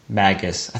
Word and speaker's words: Magus: Magus. I think we've been saying Magnus, and that's not right.